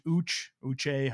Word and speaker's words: Uche: You know, I really like Josh Uche, however you say his name. – There you go. You got it.